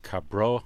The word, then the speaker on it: Cabral: The song I'd love to hear and love to play for you all is a song called Cabro. It's Portuguese, C-A-B-R-A-L, but I think the A-L is pronounced like an A-W.